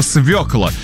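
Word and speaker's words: свёкла: В русском языке нет такого слова, есть только слово «свекла».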